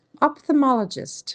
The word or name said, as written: ophthalmologist